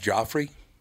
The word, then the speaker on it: Joffre: And your first name is pronounced Joffre? – You got it right, hon.